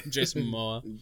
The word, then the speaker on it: Jason Momoa: Jason Momoa.